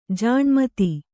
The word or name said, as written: ಜಿನಮತ